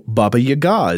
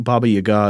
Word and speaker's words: Baba Yaga: Also, Baba Yaga is used to this end. I think Baba Yaga is like a witch in, would that be like Eastern European traditions oh, or yeah.